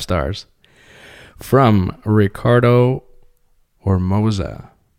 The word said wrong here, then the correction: Ormaza: Ormaza. I said it wrong. It's Ormaza.